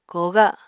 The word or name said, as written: kooga